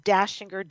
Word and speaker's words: Dachinger: And I know having an Austrian last name, Dashinger, D-A-C-H-I-N-G-E-R, what happens?